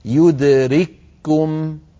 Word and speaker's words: يدرككم: لو رأيتم، لو نظرتم في المصحف الشريف تجدون الكاف الأولى مجردة والكاف الثانية عليها شدة وضمة، ونطقها كما ذكرت يدرككم، يدرككم بكاف واحدة مشددة.